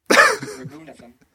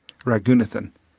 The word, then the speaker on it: Raghunathan: Raghunathan. – Raghunathan.